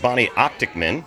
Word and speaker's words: Bonnie Optekman: All right, we are live at VOcation 2019, and I'm here with Bonnie Optekman.